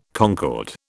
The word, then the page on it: concord: British English